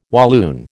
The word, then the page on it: Walloon: American English